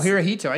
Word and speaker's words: Hirohito: It's Horihoto. – Horihoto? – No, Hirohito.